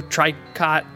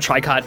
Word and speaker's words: Tricot: I've been calling them Tricot ever since I heard of them, and then I was like, it's some weird fancy knitting term, so I bet you it has some weird pronunciation. So I did the Google search and did the little, you know, click the how to pronounce it, and he's like, Trico.